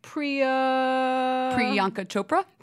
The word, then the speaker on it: Priyanka Chopra: Priyanka Chopra.